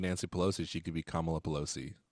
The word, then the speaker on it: Kamala: I know it's pronounced Kamala, but you could have Kamala Palooza too.